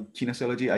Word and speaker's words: kinesiology: Oh yeah, English, it's the same, kinesiology. The same kinesiology, right? So I wanted to like keep things together with Kendo being kind of sports. If I study kinesiology, I could like in a way find again the moment to combine Kendo with my job, I thought in that moment, right?